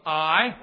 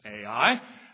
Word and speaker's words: Ai: And it's the city of Ai, or some pronounce it Ai.